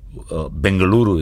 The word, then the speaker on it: Bengaluru: Bangalore was the anglicized version from the British. Bengaluru is the right pronunciation of it.